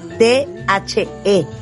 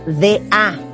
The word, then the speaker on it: the: Y muchas veces la pronuncian como D-A, da, en vez de the. – Entonces, en vez de decir the bomb, dicen da bomb.